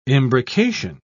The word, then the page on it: imbrication: British English